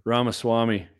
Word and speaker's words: Ramaswamy: Eric Newman, I'm going to put this one up, because Eric says, have you looked into Ramsamy? I don't even know how to pronounce his last name. – Ramaswamy.